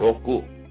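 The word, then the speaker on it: Roku (ろく): I-C-H-I. Itchy. Itchy. Okay. One is itchy. Number six is... – Roku. – He's choking on the tongue and shit. Roku. R-O-K-U. Roku. Roku. It's number six. Roku. Alright, and we're on to old Lucky Seven. Right. – Nana. – N-A-N-A.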